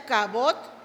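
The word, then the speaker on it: kavod (כבוד): Esta palabra honra es una palabra que viene del hebreo, se pronuncia kavod e indica peso, o sea, un peso de gloria sobre una persona.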